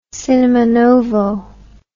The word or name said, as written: cinema novo